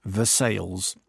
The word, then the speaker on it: Versailles: It's three syllables, and it's Versailles.